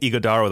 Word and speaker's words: Ighodaro: Natively, the G is silent, so it's more like Ighodaro.